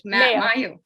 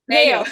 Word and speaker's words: Mayo: I don't, how do you pronounce it? – Mayo. Mayo. Ballyhornish in Mayo, yeah.